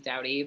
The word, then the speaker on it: Dowdy: Like ours, I don't know if you know this, so our last name is Dowdy, but we definitely get Duty a lot. – Yeah. – Which is just really disappointing because I'm like, D O U, do you pronounce loud lewd? I didn't think so. So why are you saying Duty?